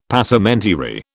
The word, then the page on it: Passementerie: British English